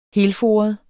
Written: helforet

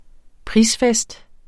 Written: prisfest

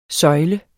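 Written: SØJLE

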